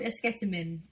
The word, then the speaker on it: esketamine: No, it's called esketamine.